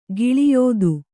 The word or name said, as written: ಘೋಳಾಯ್ತ